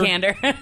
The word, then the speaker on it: Vikander: Vikander.